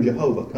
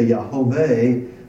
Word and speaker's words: Yahweh: Is taking these four letters, substituting the vowels or the diacritical markings from Adonai, and thus they developed this common but incorrect pronunciation of the name of God. It was later speculated that perhaps they had reversed these, and so they reversed the vowels and corrected this to Yahweh, which was eventually shortened to Yahweh.